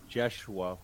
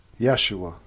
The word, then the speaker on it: Yeshua: And then in Hebrew and Aramaic, the name became Yeshua with a Y. – Yeshua.